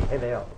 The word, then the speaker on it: hevel: That vanity, the word in Hebrew is H-E-V-E-L. And it's pronounced almost like a B, Hebel.